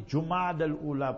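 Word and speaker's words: Jumada al-Ula: And in the same year, 3 months later, Jumad al-Ula, the month is Jumad al-Ula, pronounced properly.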